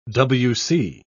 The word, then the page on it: w.c.: British English